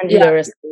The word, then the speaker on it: Anglia Ruskin: You went to Franklin, of course, but you also went to university in Cambridge, Angela Ruskin, is that how you say it? – Angela Ruskin, yeah.